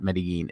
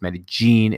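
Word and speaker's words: Medellín: By the way, Medellin is not Medellin. It's Medellin is how they pronounce it over there.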